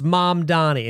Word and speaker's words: Mamdani: When he's like, here's how you say my name. It's Mom Donnie.